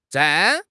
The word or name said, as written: zhan4